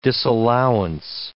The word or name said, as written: disallowance